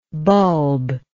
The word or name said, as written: bulbless